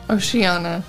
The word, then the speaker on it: Oceania: Europe, Africa, Asia, America, and Oceania. – Oceania. – Well, kind of like Rihanna. Yeah, but it's spelled different. – What? I'm just correcting you.